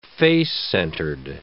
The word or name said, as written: face-centered